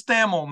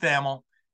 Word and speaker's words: Thamel: And by the way, it is Thamel, man. His name gets pronounced a lot of different ways, but once again, I double-checked with him before we started. It is Pete Thamel.